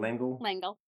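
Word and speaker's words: L'Engle: So we're looking at the 1962 novel by, I hope I pronounced this right, Madeleine Langle. – Langle.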